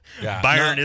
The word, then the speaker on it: Bayern: Bayern is the correct pronunciation. – I can say that because I just had the rep, and we just did a tap takeover with them, and every time I say it wrong, they pretty much smack you on the knuckles, and they say, it's Bayern. It's not Bayern, it's Bayern. And you're like, okay, fine, it's Bayern.